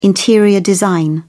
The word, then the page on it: interior design: British English